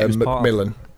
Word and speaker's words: McMillen: Uh, McMillen.